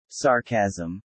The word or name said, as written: sarcasm